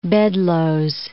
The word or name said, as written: Bedloe's Island